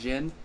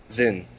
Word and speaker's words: Xin: Is X-I-N pronounced Jin? – Zen.